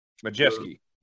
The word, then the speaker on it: Majeski: And then it was amazing that Ty McGlett, how you say his name? – Majeski. – Ty Majeski.